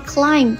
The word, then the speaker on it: climb: The paper circulation continues to climb。注意一下了，这里的 circulation 它是发行量，也表示循环的意思哦。那后面这个 climb 它已经变成了增长，向上爬，增长的意思。整个星期美元一直在升值， The dollar has been climbing all week。大家注意到没有，爬这个单词 b 它是不发音的哦。